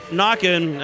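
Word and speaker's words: Naquin: So, Knockin, and people are listening, it's N-A-Q-U-I-N. – Yeah. – But, I mean, it makes sense now that you said it.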